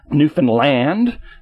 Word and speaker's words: Newfoundland: for indeed Newfoundland, as the locals prefer to emphasize the last syllable, is a veritable stockpot of Fortiana and Elliptony.